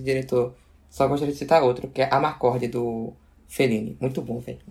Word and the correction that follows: Amarcord: sei, eu nunca sei como se fala, mas é Amarcord ou é Amacord, não sei onde tá, se eu tenho R. Amarcord. Amarcord. É. Amarcord, pronto.